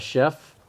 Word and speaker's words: chef: I'm guessing it's about a chef that makes donuts. – 应该是吧，应该是 chef. 好，这是一个很好字，我们现在解释一下。Chef 呢，其实就是主厨的意思。那看着这个发音啊，ch 发 sh 的音呢，想必知道它不是英文吧？ – 嗯。 – Chef，你们觉得是哪一个语言来的字？ – French. – 对，French.